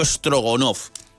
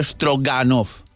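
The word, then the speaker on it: strogonoff: y ya luego nos apretamos un solomillo estrogonoff. A ver, Luis, Lucía, ¿es solomillo estrogonoff o solomillo estroganoff? ¿Cómo se dice? Exacto. Bueno, vale de las dos maneras, en realidad, se suele utilizar más estrogonoff y debe su nombre al conde estrogonoff.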